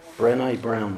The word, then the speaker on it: Brené: Am I saying her name right, by the way, Brené? – Brené Brown. – Brené Brown, fantastic, thank you.